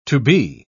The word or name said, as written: to be